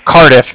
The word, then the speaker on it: Cardiff: I'm calling with the Bank of Cardiff. – Bank of what? – Cardiff. It's spelled C-A-R-D-I-F-F, as in Frank Frank, so it's like car and then different.